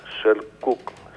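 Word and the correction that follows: Selçuk: Με σι είναι, αλλά προφέρεται Σελτζούκ.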